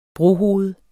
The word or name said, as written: brohoved